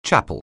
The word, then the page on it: chapel: anglais